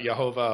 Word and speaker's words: Jehovah: Jehovah is a misunderstanding of that tradition where they actually pronounce the consonants of Yahweh with the vowels of Adonai. And it comes out Jehovah, or in English, Jehovah.